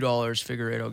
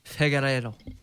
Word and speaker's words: Figueiredo: I'll bet you $2 Figueiredo gets it. – You said his name wrong. – Figueiredo.